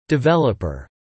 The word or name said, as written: developer